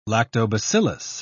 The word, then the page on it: lactobacillus: British English